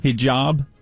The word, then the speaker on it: hijab: Hijab. – That's what it's called? – H-I-J-A-B. – Yeah, how do you pronounce that? – Hijab.